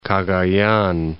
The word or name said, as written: Cagayan